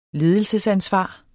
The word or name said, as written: ledelsesansvar